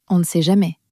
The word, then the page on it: on ne sait jamais: french